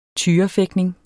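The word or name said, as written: tyrefægtning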